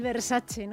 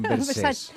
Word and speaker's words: Versace: Esto es como el Versace. – No lista. – El Versace, ¿no? Y el Versace. – Eso es, me dicen Versace. – O Versace. – Versace, no Versace.